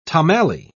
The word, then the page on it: tomalley: British English